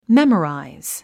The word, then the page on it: memorize: méməràiz; メモライズ